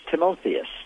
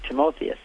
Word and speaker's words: Timotheus: His name was Timothy, or in the Greek it's Timotheus. That's how you say it in Greek, Timotheus.